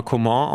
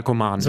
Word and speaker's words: Coman: Also ich bin sehr gespannt, weil, weil es, also man will ihn ja erstmal Coman aussprechen. Aber ich habe mir mal erklären lassen, dass es in seinem Fall Coman so, ist.